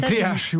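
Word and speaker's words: Le Cléac'h: Et ça, c'était détenu par Armel Le Cloaque en 2016. – Le Cléache, vous ne l'appelez pas le Cloaque.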